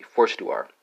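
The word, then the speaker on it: forstår: Danish, what would that be if I tried the pronunciation right? That'd be forstuar. That'd be a forstuar, yeah. If I tried the Danish right.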